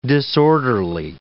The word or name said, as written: disorderly